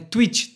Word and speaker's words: Twitch: Uma das coisas mais legais do Twitch é não, não confundir com Twitter e nem com um tweet de quando alguém twitta, né? É Twitch, T-W-T-I-C-H. Falei certo? Falei certo.